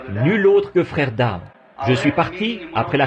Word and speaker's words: Daugh: Et à vrai dire, son nom, c'est D-A-U-G-H. Mais moi, je le prononce simplement Dow, vous voyez.